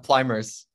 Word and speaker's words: Pluymers: Yeah, Pluymers.